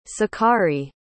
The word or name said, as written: sakari